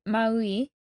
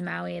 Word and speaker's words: Maui: Most the likely pronounced Maui, as in like each vowel is really pronounced, versus the Americanized Maui that we say.